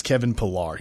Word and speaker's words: Kevin Pillar: And I said his name wrong. It's Kevin Pillar.